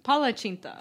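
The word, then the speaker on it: palacsinta: How do you say it? – Palacinta. – Palacinta. Did I say that right? – Yeah. It's their word for crepe.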